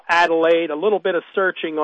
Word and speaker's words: Adelaide: But I will spell it for those of you who want me to spell it out. It's A-D-E-L-A-I-D-E. A-D-E-L-A-I-D-E. Adelaide Institute, just like it sounds, .org.